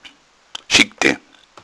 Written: schicken

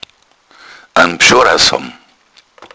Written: bschorrass

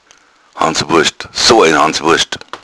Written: hanswurst

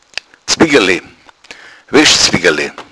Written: zwiggale

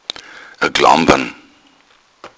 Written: klampern

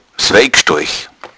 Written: wein

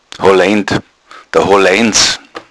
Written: holleind

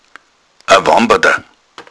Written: wambada

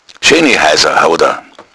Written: heissa

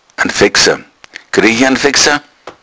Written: veggsa

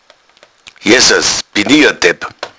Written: jessas

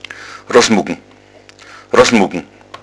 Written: rossmuggn

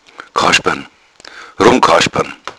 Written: rummkaspern

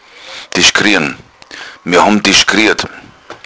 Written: dischkrieren

